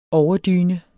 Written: overdyne

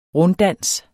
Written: runddans

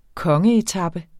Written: kongeetape

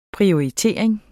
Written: PRIORITERING

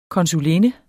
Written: konsulinde

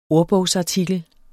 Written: ordbogsartikel

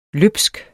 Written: løbsk

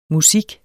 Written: MUSIKKEN

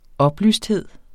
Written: oplysthed

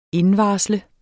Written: indvarsle

